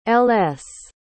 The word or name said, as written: ls